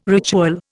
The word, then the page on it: ritual: Indian